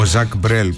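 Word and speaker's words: Jacques Brel: Ο Ζακ Μπρέλ που θα έλεγα εγώ, για δεν ξέρω και γαλλικά, αλλά κάπου μου είπαν ότι αυτή είναι η ορθή προφορά και τα λοιπά.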